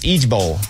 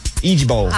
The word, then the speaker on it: IJBOL: It's pronounced Idge Bowl. Idge Bowl. – It stands for something? – Mm-hmm. I-J-B-O-L.